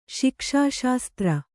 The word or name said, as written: ಶಿಕ್ಷಾಶಾಸ್ತ್ರ